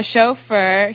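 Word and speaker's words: shofar: So, S-H-O-F-A-R. A chauffeur, Hebrew, pronounced, I don't know how to pronounce that. Can you say the Hebrew? – Chauffeur. Chauffeur.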